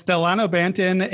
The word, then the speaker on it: Delano Banton: It's Delano Banton,